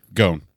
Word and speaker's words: Gown: So throughout the majority of the first season, you have Ainz, who Gown. I keep mispronouncing it. – Gown.